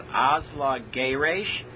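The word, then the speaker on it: Azla Geresh: It's called Asla Geresh. I sing it exactly like V'asla. V'asla. But it's not preceded by a kadma. And also, it's always accented on the second from the last syllable.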